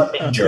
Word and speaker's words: Harbinger: Harbinger, yeah. Not Hard. Yeah. Harbinger is the way to pronounce it.